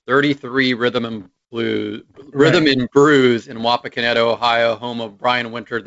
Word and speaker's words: Wapakoneta: Can you pronounce that again? What? – Wapakoneta. – Wapakoneta. Okay. – Wapakoneta. And people call it Wapak or Wapak. Okay. So that's kind of the short.